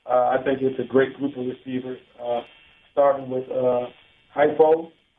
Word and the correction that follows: Hefo: Some of these first names, I don't want to butcher them. – Yeah, Aleva Hefo. – Hefo, excuse me.